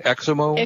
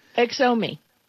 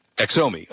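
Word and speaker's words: Xiaomi: And I, yeah, and I, I, don't know how to pronounce it. IOMO, Exomo? – Ex- Exomi. – Exomi. Okay, Exomi was uh, up 32%, and the company called Oppo, which I don't know who that is either.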